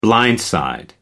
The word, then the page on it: blindside: American English